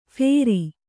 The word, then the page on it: ಫೇರಿ: phēri